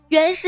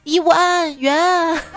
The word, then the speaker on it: 圆: 突然，我家孩子一咕噜爬起来，跑到阳台，打开窗子，对着对面喊：“ 程程，你别听你妈妈的，圆是整体认读音节，就读圆，不读一无安圆。”